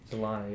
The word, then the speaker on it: Solana: Is it Solana? Solana, yeah. I've been saying it wrong. Solana. – Solana, dude.